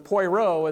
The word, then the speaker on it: Poirot: but Poirot is how we sound in English. So that's as far as I'm going to do. As far as I'm going to go is trying to pronounce the French.